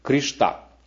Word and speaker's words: Кришна: В Индии Кришна часто произносится как Кришта. Кришта, вот так в некоторых штатах называют.